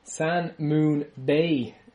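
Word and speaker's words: Sangmoon Bae: San Moon Bay, is that how you say it? – Yep.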